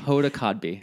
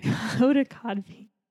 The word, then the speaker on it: Hoda Kotb: Hoda Kotb. – Hoda Kotb.